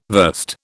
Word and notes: verst: British English

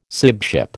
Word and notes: sibship: American English

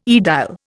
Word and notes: aedile: Indian

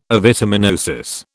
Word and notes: avitaminosis: British English

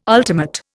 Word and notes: ultimate: Indian

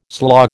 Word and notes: slaw: American English

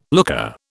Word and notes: looker: British English

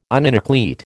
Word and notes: uninucleate: American English

